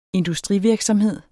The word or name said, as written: industrivirksomhed